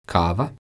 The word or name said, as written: kava